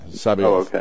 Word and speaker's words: Sabaoth: Sabaoth? – Yeah, Sabaoth. – Oh, okay. – Yes. – Interesting. – Oh, yeah. It really messes up on hymns because you got to get another syllable in there.